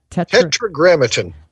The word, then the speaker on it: tetragrammaton: A tetragrammaton.